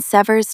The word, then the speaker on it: sever: When an organization severs ties with a vendor, that partnership is entirely over. Stress the first syllable. – Sever.